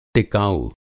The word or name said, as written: टिकाऊ